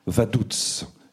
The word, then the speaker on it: Vaduz: Il dit Vaduz. Et c'est exactement la prononciation locale. Vous savez, c'est un allemand spécial, le liechtensteinien. Donc, il le dit à la liechtensteinienne. Vaduz.